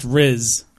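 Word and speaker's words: Rhys: I mean, isn't Rice something that most people eat or perhaps throw at weddings? – I'm not sure. – Okay, but I think his name is – I think it's actually pronounced Riz.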